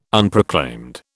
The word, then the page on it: Unproclaimed: British English